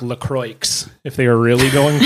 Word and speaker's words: LaCroix: So the thing with that is apparently it's actually pronounced LaCroix because it's like a river in like Minnesota or Wisconsin or something.